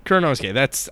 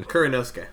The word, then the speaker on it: Kuranosuke: I can never pronounce her name correctly because she's got a long name. – Just known as Karako. – Kuranosuke? – Yeah, Kuranosuke. I think that's how it's said.